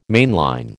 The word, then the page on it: mainline: American English